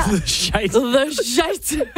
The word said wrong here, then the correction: The Scythe: Ej, hvordan siger man det? Jeg er kommet fint udenom aldrig at skulle udtale det her navn i radioen før. – Nu er jeg faktisk blevet en lille smule tvivl, fordi at du putter mig on the spot. Ja. Den hedder The Scythe.